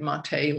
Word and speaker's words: Maté: I think that's how you say it, Maté, like accent de you.